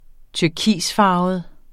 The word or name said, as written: turkisfarvet